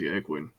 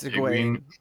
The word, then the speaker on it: Egwene: And I expect you're eager to see Egwene. And I said that wrong. – Yeah. – Egwene. – It's Egwene.